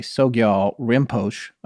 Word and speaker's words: Sogyal Rinpoche: Sogyal Rinpoche." I'm not sure if I'm saying his name correctly, but, and then she said— Yeah, you said it right.